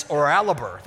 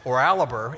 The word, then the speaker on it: Oralabor: It took me probably six weeks to figure out how to pronounce Orlabor Road. It's Oralabor. That's how you pronounce it. Oralabor. Anywhere else in the country, it wouldn't be Oralabor, but you say Oralabor, and people look at you like there's something wrong with you you ask questions like, where do you shop?